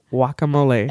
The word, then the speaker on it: guacamole: If you like me even a little bit, promise never, ever to pronounce the G in guacamole.